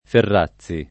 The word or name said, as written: Ferrazzi